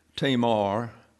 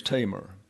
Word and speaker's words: Tamar: By the way, the pronunciation of the daughter is Tamar. Oftentimes you hear it pronounced Tamar, but the accent is on the first syllable. And therefore, I go to all of the dictionaries for these names, either given names or surnames, to make sure I pronounce them correctly in my presentations to you. So the lady's name is Tamar, not Tamar.